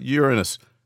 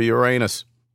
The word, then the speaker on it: Uranus: Got through the whole story without any quips about Uranus or Uranus. You know, both are actually accepted pronunciations, but saying Uranus, you know, that could help avoid immature giggle fits over Uranus.